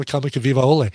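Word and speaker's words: Kamakaviva'ole (Kamakawiwoʻole): Israel Kamakaviva'ole.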